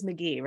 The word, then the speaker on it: McGee: It is McGee, right? – Yes.